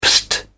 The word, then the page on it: psst: British English